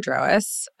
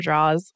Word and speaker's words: Drawas: So my agency's name is Walker Drawas. – Um, I've been um, saying it wrong. I've been saying Walker Draws.